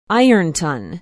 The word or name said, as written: ironton